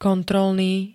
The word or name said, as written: kontrolný